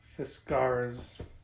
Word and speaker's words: Fiskars: Fiskars. – Rhymes with Whiskars.